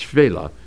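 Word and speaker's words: Shephelah: It looks like Shephela, but it's pronounced Shvela.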